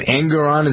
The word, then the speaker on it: Engoron: Engoron, is that how you pronounce his name? – Uh-huh, that's right.